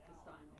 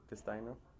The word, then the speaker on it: Castagno: Castano. C-A-S. – Uh-huh. – T-A-G. – E-G. – N? – N-O. N-O. How would you say that again? – Castano. – C-A-S-T-A-G-N-O.